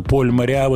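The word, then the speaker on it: Поль Мориа: По поводу Пола Мариа, Поля Мариа, действительно, Поль Мариа, вот это французское произношение – оно как бы закрепилось.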